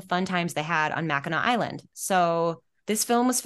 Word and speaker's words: Mackinac: Yeah, so it looks like it's spelled Mackinac, people at home, but it's pronounced Mackinac. That's just how it's pronounced, Mackinac.